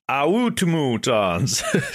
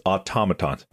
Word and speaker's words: automatons: Some go like, automatons, automatons, autumutons. It's automatons. So